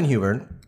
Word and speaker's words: Huber: Simon Huber, and please correct my pronunciation if I'm pronouncing that wrong. – No, you've got it.